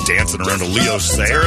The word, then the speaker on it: Leo Sayer: The one and only Leo Sayer.